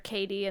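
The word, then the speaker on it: Kaity: Because usually they hear Katie, and they'll spell it whatever way they want to spell it, and then I'll have to respell it.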